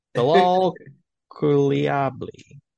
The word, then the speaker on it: Bilal Coulibaly: Butcher that name? – The way you said it so slow, it worked. I liked it. – Bilal Coulibaly.